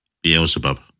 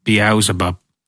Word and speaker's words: Beelzebub: No, how do you pronounce that? I don't want to get possessed. – Beelzebub. – Beelzebub.